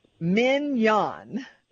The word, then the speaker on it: Mignon: And finally he goes, look, her name is pronounced like the steak. Mignon, Mignon.